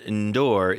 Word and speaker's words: N'Dour: Y-O-U-S-S-O-U, Youssou. And then N'Dour is N'Dour.